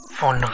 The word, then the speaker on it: Phaune: Phone radio. I mean, phone radio. It's spelled P-H-A-U-N-E. But it's pronounced phone.